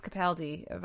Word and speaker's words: Capaldi: I'm hearing it's Capaldi, by the way, so I think I've been saying it wrong for years.